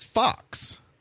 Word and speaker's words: Fuchs: And she, their last name is Fox, but it's spelled F-U-C-H-S. – It's not Fuchs? – Nope. It's pronounced Fox. F-U-C-H-S.